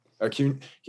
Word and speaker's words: Acuña: And I was very fortunate to get, is it Ronald Acuña, or how do you say his last name? – Yeah. – Acuña.